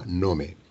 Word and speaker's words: Nome: Entonces, eh, nada, simplemente vas eh, desde Anchorage, que es la capital de Alaska, hasta un pueblo que se llama Nome, que se pronuncia Nom.